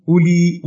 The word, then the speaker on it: أولي: 2- أتأمل الكلمتين أولي وأولات في قوله من أولي العزم وهل تلد أولاة الخمار، وهما اسمان ملحقان بالجمع المذكر السالم بمعنى أصحاب وصاحبات، وقد لحقهما واو مزيدة على الأصل لا تنطق، ولا فرق في ذلك أن تكون مجرورتين أو مرفوعتين أو منصوبتين.